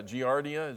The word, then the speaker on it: Giardia: Yeah, Giardia, is that how you pronounce it? Yeah, you know, beaver fever.